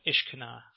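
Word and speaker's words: Ishkanah: I believe the experts call it Ishkana.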